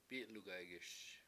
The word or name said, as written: p7I7lugaygiS